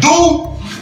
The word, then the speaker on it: Доу: Я не знаю, как его правильно читать, но он пишется как, ну, да, как Гомер говорит, Доу.